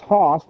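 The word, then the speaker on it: hos (ὡς): The head, kakale, is the head, the kakale, of the wife, as, and that's hos, there should be a long O, should be a mark over the O, that's an adverb of perfect comparison.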